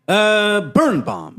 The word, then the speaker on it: Birnbaum: Birnbaum. – Birnbaum. – Yeah. – First part, burn. As in what happens if fire touches something. And the last bomb is if you were to drop a detonated weapon.